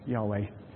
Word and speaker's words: Yahweh: Now we pronounce that, we spell that, Y-A-H-E-W-H. Have I got that right? Yes, Yahweh.